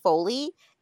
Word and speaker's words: Foley: but I read The Guest List by Lucy Foley, and it's a mystery thriller, whatever.